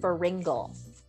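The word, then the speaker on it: Feringa: By the way, his last name is pronounced Feringel. – Feringel. – Mm-hmm. Correct. – In my mind, I named it Flugelhorn. – No, it's Feringel. That is exactly how it's pronounced. It's pronounced Dan Feringel.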